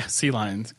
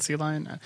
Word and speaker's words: CLion: Yeah, CLion. Client, CLion.